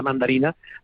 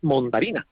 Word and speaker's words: mandarina: Pero hay, por ejemplo, palabras que no sé si cunde por España, pero en Andalucía se pronuncia mucho para designar a la mandarina, la palabra mondarina.